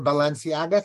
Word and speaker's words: Balenciaga: The Balenciaga thing? – Oh, look how good you pronounced that. That's great. – I just made that one up. I have no idea. Is that how to pronounce it? – Yes, you did good.